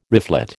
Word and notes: reflet: American English

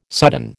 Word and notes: sudden: American English

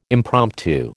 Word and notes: impromptu: American English

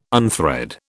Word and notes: unthread: British English

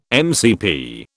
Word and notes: MCP: British English

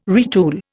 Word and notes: retool: Indian

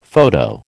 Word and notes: photo: American English

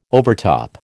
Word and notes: overtop: American English

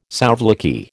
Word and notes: souvlaki: American English

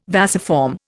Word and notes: vasiform: Indian

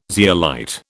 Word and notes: zeolite: British English

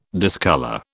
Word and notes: discolour: British English

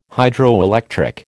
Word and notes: Hydroelectric: American English

